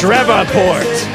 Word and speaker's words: Shreveport: It's pronounced Shreveport, says Asmodora with the super chat.